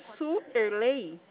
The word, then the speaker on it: Suerlei: Oi, eu quero falar com a Suerlei. – Pois não. – É assim que se pronuncia? – É.